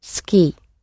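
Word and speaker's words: suki (好き): The word for I like in Japanese is suki. Suki is spelt S-U-K-I. The U sound in it is hardly spoken. It can sound very squashed, as in ski, or a little bit more pronounced.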